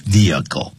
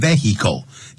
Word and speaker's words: vehicle: ，vehicle 啊，不要念成 vehicle，那个 h 不发音的，Andrew 说了。